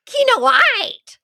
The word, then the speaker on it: Kinoite: Kinoite.